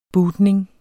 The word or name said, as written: bootning